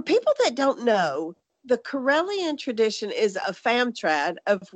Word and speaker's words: Karelian: Nativist tradition, am I saying that right, Ed? – That is correct. It's Karelian nativist tradition.